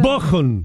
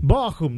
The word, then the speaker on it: Bochum: Entonces, ¿cómo se pronuncia? – Bochum. – Bochum, listo, ahí está.